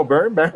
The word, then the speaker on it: Byrne: Perfectísimo, Byrne.